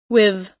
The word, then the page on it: with: American English